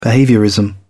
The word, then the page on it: behaviourism: British English